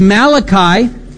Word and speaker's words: Malachi: I always thought it was Malachi. Okay, no. It's Malachi. He's not Italian.